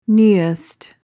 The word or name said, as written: neoist